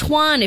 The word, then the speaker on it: Antoine: We got a young prospect in Adorati, and again, we're getting a 25-year-old young player in Antonio Beauvillier. – Butchered all of it. He's a French guy. – Butchered. – It's Antoine, if you're going to do anything fancy there.